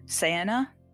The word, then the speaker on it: Ceana: Para, P-A-R-A, and I think it's pronounced Sienna, C-E-A-N-A.